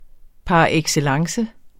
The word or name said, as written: par